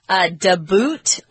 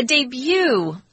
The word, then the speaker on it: debut: You notice we don't say debut or debut. – Debutted. – It's a French word, so we're keeping the French pronunciation. They tend to leave off the final consonant in words. So a debut, he made his debut.